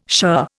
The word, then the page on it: shirr: Indian